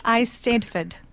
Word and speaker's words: Eisteddfod: Tell me about these regional Estetafudas. – So it's pronounced Eisteddfod.